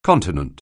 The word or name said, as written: Continent